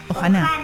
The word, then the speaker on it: Ohana: Ohana.